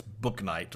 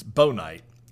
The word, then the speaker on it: Bouknight: Uh, if you look at the way his name is spelled, it looks like it's Book Knight or Boke Knight, but it's Bo Knight.